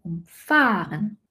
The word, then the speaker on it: umfahren: Umfahren. Wenn ich jemanden umfahre, ist die Betonung auf der ersten Silbe.